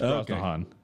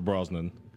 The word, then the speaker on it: Brosnahan: It's Brosnahan. – Miss Maisel, huh? – It's Brosnahan. – Mrs. Maisel. – I am also going with Rachel Brosnan. – You said it wrong.